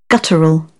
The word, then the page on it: guttural: British English; əɹ